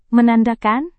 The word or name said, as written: menandakan